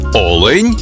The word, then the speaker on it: олень: в однині, і в множині наголос падає на перший склад – олень та олені.